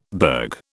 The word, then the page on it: burg: British English